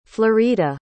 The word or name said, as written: florita